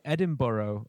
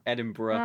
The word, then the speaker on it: Edinburgh: So Americans say Edinburgh, like David Attenborough. But we say Edinburgh? – Edinburgh.